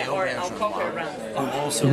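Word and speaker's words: Auberjonois: Hi, this is a very short video greet from Rene Aubergeois. – Rene Aubergeois. Yeah, so I've been saying it this wrong my entire life because I've always pronounced it Aubergeois. – Mm-hmm. – Which isn't even fucking close.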